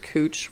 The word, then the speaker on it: Couch: So you know how, like, in Portland, there's Cooch Street, which is, right, like, couch, but it's pronounced Cooch for some reason.